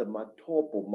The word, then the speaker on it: Matobo: Matobo, wrongly pronounced Matopo in, in Zimbabwe.